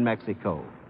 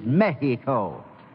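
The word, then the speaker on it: México: I know for a fact that you two have never been in Mexico. Why, you don't even know how to pronounce it right. The correct Spanish pronunciation is Mexico. Mexico.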